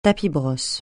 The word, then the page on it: tapis-brosse: francais